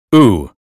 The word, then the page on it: blue: American English